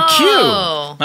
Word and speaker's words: McHugh: The name is spelled capital M, C, capital H-U-G-H, Marvin McHugh. – Marvin McHugh.